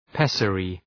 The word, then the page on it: pessary: ‘pesərı